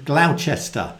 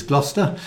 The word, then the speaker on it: Gloucester: He actually met Barry, well, he was in the same room as Barry in Gloucester in in May. That's how Barry pronounces Gloucester, when Barry and Anne were over.